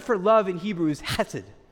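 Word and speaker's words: chesed (חסד): Turn to the person next to you and say, chesed. Make sure you spit on them to say it right. You got to have a little bit of saliva to do it well.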